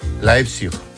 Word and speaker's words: Leipzig: ¿Cómo se dice? – Leipzig. – Leipzig. Leipzig, ¿ves? – Leipzig, muy bien.